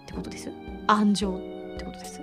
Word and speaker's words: 安城: 一般的には前側を強く発音していますが今ねすごくそこのところが太文字になっていたのであの「岡」と「あん」と「ち」と「に」がねわざと岡崎安城知立西尾って読んだ見たんですが地元では平坦に読んでいるんです。